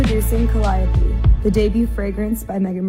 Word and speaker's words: Calliope: Which could either be, when I read that, I think Calliope. But Calliope. – No, it's Calliope.